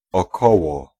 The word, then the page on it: około: [ɔˈkɔwɔ]